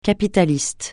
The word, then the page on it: capitaliste: francais